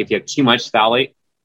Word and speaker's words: phthalate: So one big one, for instance, that's a carcinogen in an endocrine disruptor is the phthalate. It's spelled very funny. The P is silent.